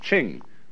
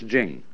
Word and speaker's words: Jing: And Jing, there is no apostrophe after the C-H, so if there were, it would be pronounced Ching, but it's Jing. Like a J.